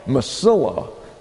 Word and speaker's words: Mesilla: We have Mesilla. That's spelled M-E-S-I-L-L-A.